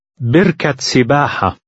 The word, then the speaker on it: بركة سباحة: The ta marbuta is pronounced as a T at the end of the first word, but not the second. – بركة سباحة.